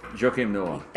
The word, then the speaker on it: Joakim Noah: Joakim Noah.